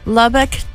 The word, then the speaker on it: Lubbock: Lubbock, Texas.